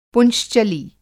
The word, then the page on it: पुंश्चली: Hindi